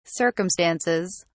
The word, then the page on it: circumstances: British English